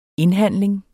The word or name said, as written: indhandling